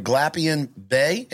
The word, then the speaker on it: Glapion-Bey: Her name is Lisa, and I'm not sure how to pronounce it, but I'm going to go for it. Glapion-Bey.